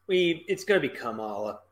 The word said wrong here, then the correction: Kamala: or excuse me, I think her name is pronounced Kamala, like I give a shit.